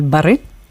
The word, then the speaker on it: barre: se está viendo el barre, creo que se pronuncia así, Eso es, sí.